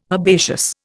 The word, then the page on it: herbaceous: Indian